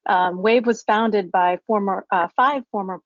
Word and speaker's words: WAEV: So earlier this week, EV startup Wave Inc., and that's spelled W-A-E-V, acquired